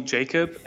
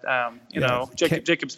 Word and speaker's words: Jakub: Most of my American friends, they just call me Jakub, and this is great. They don't even try to pronounce my last name, but, Jakub's perfect. – K-R-Z-Y-C-H.